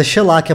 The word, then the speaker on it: Shellac: E a gente ouviu Shellac, é Shellac, eu sempre falo errado também, mas é Shellac a pronúncia do...